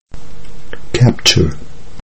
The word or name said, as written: capture